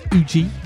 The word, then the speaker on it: Ujjy: Um, really enjoying the, I think it's pronounced Uji UJJY album.